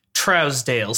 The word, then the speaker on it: Trousdale: So I looked up an interview. I watched interviews until I found one where he introduces himself and says, "Hello, I'm Gary Trousdale." So even if the name should be pronounced Trousdale or is pronounced Trousdale by some people, he says Trousdale.